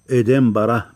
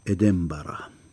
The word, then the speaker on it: إدنبرة: ننطق إدنبرة بكسر الهمزة مع الإمالة وبفتحة على الباء فهكذا يلفظها أهلها وهم مثلنا يكتبونها نونا وينطقونها ميما إدنبرة